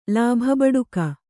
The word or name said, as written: ಲಾಭಬಡುಕ